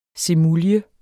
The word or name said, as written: semulje